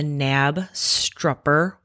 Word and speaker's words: Knabstrupper: Have you ever heard of the Knabstrupper? – Spell that. – K-N-A-B-S-T-R-U-P-P-E-R. – And pronounce it again? – Knabstrupper?